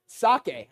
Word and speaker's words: sake: Yesterday, I pronounced that sake, S-A-K-E, and then people are like, sake. I'm like, oh, sake, okay.